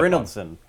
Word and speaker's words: Brynildson: Brynildson. – Brynildson. Let me see. – It's like Bryn Mawr. Ilson. – All right. And now becomes the deliberation. – Brynildson. – Brynildson, yeah.